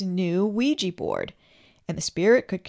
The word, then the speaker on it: Ouija: Take the Ouija board, pronounced Ouija.